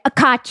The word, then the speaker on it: Koch: Yeah, Koch.